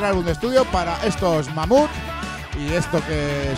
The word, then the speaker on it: Mammoth: Mamut, que en inglés pues se dice también así, mamut. Se escribe de otra manera, pero suena igual.